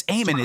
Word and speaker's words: Eamon: She Iman is. – Is it? It's Eamon, isn't it? I think he pronounces it Eamon, right? – Eamon, maybe it's Eamon.